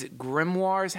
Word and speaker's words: grimoires: by in so many of these grimoires. How did you say grimoire? Because I haven't mispronounced. – Like you said it, yeah.